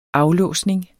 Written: aflåsning

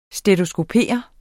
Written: stetoskopere

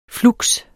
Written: flux

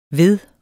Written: ved

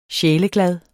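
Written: SJÆLEGLADE

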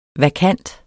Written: vakant

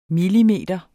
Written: millimeter

